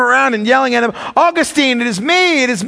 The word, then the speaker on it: Augustine: Augustine, or Augustine, that's the wrong pronunciation, it's Augustine.